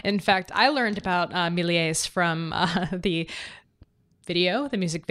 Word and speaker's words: Méliès: Gunn, Georges Méliès, Jean Chanher. How do you say it? – I think you actually say the S in Méliès. Yeah, I believe it's like that.